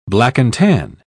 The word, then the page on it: black-and-tan: British English